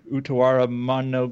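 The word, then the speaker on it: Utawarerumono: And then, like, the Utawara Mono games, if I pronounce that anywhere close to correctly. – You got it right, yeah.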